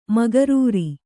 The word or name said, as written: ಮೊಕರರು